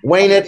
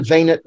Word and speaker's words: venit: Well, venit in classical Latin, venit in Catholic Latin. All right. How about he is afraid? – I forget. – Timet. – T-I-M-E-T.